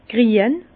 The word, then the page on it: grien: Bas-Rhin; Herrlisheim